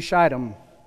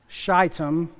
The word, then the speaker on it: Shittim: So with that, Joshua, son of Nun, secretly sent two spies into Shittim. It's a long I, Ragers. Shittim, long I.